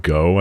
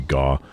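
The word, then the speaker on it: Gaugh: Where, now I know that they came back, Eric Wilson and Bud Go, I think is how you pronounce it. Spelled G-A-U-G-H. I think it's pronounced Go. Or maybe Gaw.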